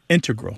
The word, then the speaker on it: integral: But the mathematicians and math using scientists are more likely to say integral with the stress on the first syllable.